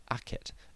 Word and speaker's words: Akhet: This glyph, which shows the sun setting between two mounds, is pronounced Akhet and is the logogram for the word horizon.